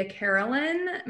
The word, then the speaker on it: Carolan: All my social media is just under at Julia Carolan. My last name is spelled Carolan.